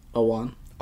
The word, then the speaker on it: Ewan: How do you pronounce his name? – Ewan. – Ewan? – Ewan? – Ewan McGregor? – Ewan. Ewan McGregor.